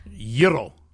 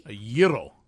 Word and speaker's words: gyro: Okay, so the question while you were out of the studio, how do you say the lamb's meat that comes in a pita? – Gyro. – Oh, lovely. – A gyro. – A gyro. – I think similarly, like gyro.